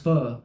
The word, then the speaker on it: pho: Where I'm staying, it's called, you know, because it's pho, but it's pronounced pho.